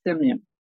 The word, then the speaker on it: Simeon: and Rupert Paul Simeon.